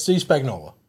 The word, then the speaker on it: Spagnuolo: Defensive coordinator, Steve Spagnuolo. Is that how you say it? – Yes, Steve Spagnuolo.